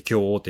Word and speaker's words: 京王: 京王線、その、京王っていうのは、まあ、教える王って書いて京王って読みますけど、まあ、一体何なのかって言いますと、その、六辺報告の中で一番偉い人、まあ、日本で言えば、まあ、天皇みたいなもんですね。